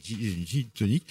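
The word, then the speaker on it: Jean Tonique: Alors, Gin Tonic, oui, parce que ça s'écrit J-E-A-N comme Jean, mais c'est Gin Tonic.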